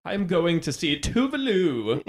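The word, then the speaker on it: Tove Lo: So I actually have had Tovlo on repeat a little bit. – Someone told me that it's pronounced Tuvlu. Because she's Swedish, right? – Tuvlu.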